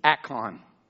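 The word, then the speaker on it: ikon (εἰκών): Ikon. E-I-K-O-N. I listened to the Hebrew word, so I pronounced it, I believe, right? It's ikon. Image.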